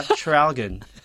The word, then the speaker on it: Traralgon: Yung pala, Traralgon ganun pala siya eh, i-pronounce.